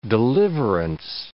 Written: deliverance

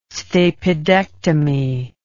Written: stapedectomy

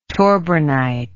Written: torbernite

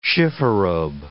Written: chifforobe

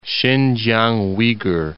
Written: Sinkiang Uighur

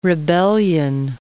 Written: rebellion

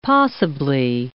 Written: possibly

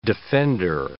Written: defender

